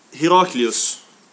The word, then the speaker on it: Heraklius: Och hans namn uttalas Heraklius. Och det är inte Herkules. Inte samma person.